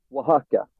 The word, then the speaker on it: Oaxaca: Jag är så osäker på uttalet här. Hur säger du? Oaxaca? – Oaxaca. – Oaxaca. – X är tusen med två. – Okej, Oaxaca. Och det ligger då i Mexiko och Mexiko ligger i Nordamerika.